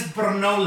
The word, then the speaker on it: Brno: It's B-R-N-O.